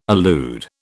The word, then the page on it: allude: British English